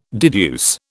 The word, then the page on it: deduce: British English